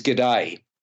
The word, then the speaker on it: Gede: Once she arrives in Bali, Lily falls head over heels for a local seaweed fisherman whose name is G'day, which I thought was a nice touch, but G'day is spelt G-E-D-E, so there you go, played by Maxime Boutier.